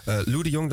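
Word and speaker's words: Loe de Jong: Lou de Jong, dat was de hoofdomroeper van Radio Oranje.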